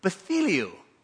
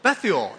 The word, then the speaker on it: Bethuel: How do you know how it's pronounced? Bethuel. No. Bethuel, yeah, answered.